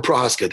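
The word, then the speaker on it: Procházka: How'd I do that with Prochazka? Did I pronounce that okay? – I think you nailed it, actually.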